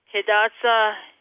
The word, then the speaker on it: Hidatsa: So can you say them correctly so everyone knows? – Yes, it is the Mandan Hidatsa and Arikara Nation.